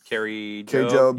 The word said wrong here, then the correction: Kari Jobe: And so, but then, excuse me, Kari Jobe. – Kari Jobe. I think it's a soft J.